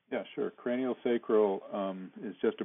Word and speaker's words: sacral: Now, it's cranial sacral. Is that correct? Am I saying it right? – Sacral, cranial sacral. – Sacral.